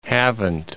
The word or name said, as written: Havant